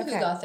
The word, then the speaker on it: hygge: Spelled H-Y-G-G-E. Pronounced hygge because it's a different language than English.